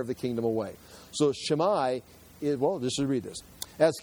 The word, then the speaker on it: Shimei: Arlen, you got a pronunciation, Shimei? Okay, so. Okay, so say it for me. So, Shimei.